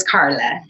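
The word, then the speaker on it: Karlai: It's K-A-R-L-A-I. – Thank you, Carla. And it is pronounced Carla, right? – It is Carla. Yes.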